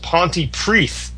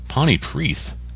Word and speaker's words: Pontypridd: He says, good luck with that. Pronounced Pontypreath. – Wow. – Why? – Pontypreath.